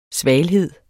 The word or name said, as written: svalhed